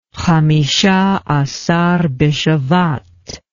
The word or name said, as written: Hamishah Asar Bishevat